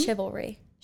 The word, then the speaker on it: chivalry: Is that how you say the word? Or is it chivalry? – Chivalry.